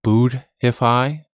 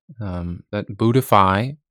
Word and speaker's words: Buddhify: So with that in mind, I'm going to pronounce this app name again. Buddhify. Buddhify. This is spelled Buddhify.